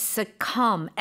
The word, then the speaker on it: succumb: The spelling is succumb. S-U-C-C-U-M-B. Mm-hmm. Succumb to something. – B는 발음이 안 되는 거죠, 그렇죠? – Yes, right. that's right.